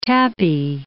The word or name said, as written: tapis